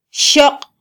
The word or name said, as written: xoq